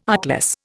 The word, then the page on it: artless: Indian